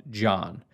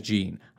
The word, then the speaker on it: Jean: And on that sheet from the Green Bay Packers, it says his name is pronounced John. I'm sorry, I heard the man say his name is Gene. I'm calling him Gene. Every announcer that went to App State called him Gene. He calls his name Gene.